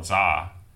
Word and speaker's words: Zha: You can follow him on Twitter at DanCohen3000 and also follow me on Twitter at Carl Zha. Carl is spelled with a C. Zha is my last name, spelled Z like zebra, H like Henry, A like apple.